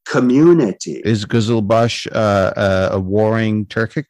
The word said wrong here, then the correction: Kizilbash: I, I, think we can say that the history of the Kizilbash involves a military, uh, Turkic kind of tribal military force in Safavid Iran that also had bureaucratic and administrative skills and literacy.